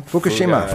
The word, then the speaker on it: Fukushima: That Fukushima, how do you say the name? – Fukushima. – Fukushima. Yeah.